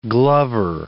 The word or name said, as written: glover